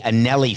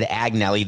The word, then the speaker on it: Agnelli: The owner of Juventus is the Agnelli family. I said Agnelli. The G is silent.